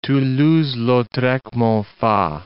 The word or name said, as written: Toulouse-Lautrec (-Monfa)